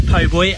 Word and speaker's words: po' boy: Or is it po' boy? How do you say it? – It's a po' boy, and it's basically an abbreviation of poor boy.